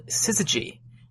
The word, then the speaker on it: syzygy: This alignment of three celestial objects in a row is called syzygy, and that's spelled S-Y-Z-Y-G-Y.